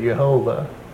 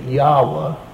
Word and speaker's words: Yahweh: But since those translations were completed, Bible scholars are pretty well in agreement that that was not the way that the Hebrews actually pronounced the name, that Yahweh comes closer to approximating the pronunciation.